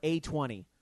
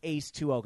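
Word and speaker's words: A20: It's called DDR Ace 2-0, or A-20, if you want to be very American about it. A-20. But I guess it's actually pronounced Ace 2-0, because last DDR was... Ace, so I guess this is Ace 2.0, something like that.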